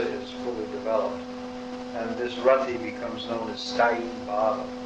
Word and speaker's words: Rati: The conclusion is that bhava bhakti, or Rati, another name for this bhava or ecstasy is called Rati, R-A-T-I, Rati, short A, Rati, is prema, love of God in its budding stage.